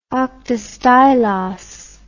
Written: oktastylos

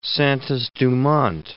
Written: Santos-Dumont

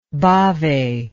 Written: Bhave

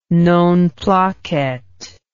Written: non placet

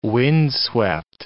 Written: windswept

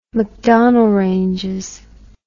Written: macdonnell ranges

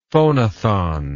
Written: phonathon

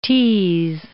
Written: tease

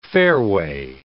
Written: fairway

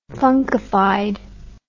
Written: funkified

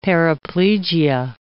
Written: paraplegia

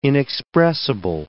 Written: inexpressible

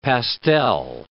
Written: pastel

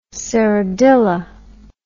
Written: serradilla

